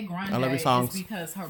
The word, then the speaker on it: Grande: And I think she pronounces it, her family pronounces it Grande or something versus Grande, like we all say.